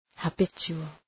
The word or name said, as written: habitual